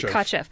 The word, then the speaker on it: Kotcheff: I'd heard that the director, Ted, how do I say it? – Kotcheff.